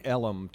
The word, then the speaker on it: Ellum: Now explain Ellum to people who are not from Dallas, though. It's how Elm is pronounced.